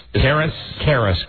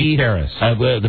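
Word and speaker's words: Carys: Karis. – Karis. – The pronunciation phonetically is K-E-H-R-I-S. – Oh, babies are so cute. – Karis.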